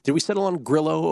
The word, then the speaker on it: Grillo: All right, we'll go with Grillo. – I'm usually pretty good with names, but he's one I feel bad about almost every time I say it. – Yeah, the Argentine pronunciation should be Grigio, but oh well.